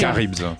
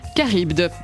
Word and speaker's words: Charybde: Charybde. – Charybde, très bien.